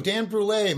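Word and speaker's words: Brulé: Dan Brule, my grandfather would be very happy up in Canada to pronounce it. – I apologize for that. – Yeah, it's like creme brulee.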